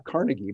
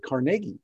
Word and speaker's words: Carnegie: And I learned that's the way they pronounce it, not Carnegie, but Carnegie, because he's from Scotland and that's the way they did it.